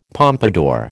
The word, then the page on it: pompadour: American English